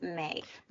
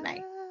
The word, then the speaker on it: Mai: No, you're totally fine because actually M-A-I probably should be pronounced Mai, but because it's my initials, it's Meredith Ann Ireland, I pronounce it May.